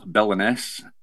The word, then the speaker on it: Billinness: If you want to find my music on Spotify or iTunes or whatever, search for Neil Billiness, and my surname is spelled B-I-L-L-I-N-N-E-S-S.